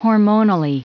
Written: hormonally

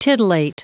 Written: titillate

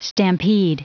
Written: stampede

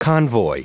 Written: convoy

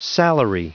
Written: salary

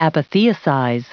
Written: apotheosize

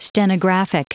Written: stenographic

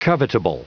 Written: covetable

